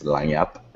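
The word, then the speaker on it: Lagniappe: You pronounce it in French, I believe. It's Lagnap. A lot of people get that wrong.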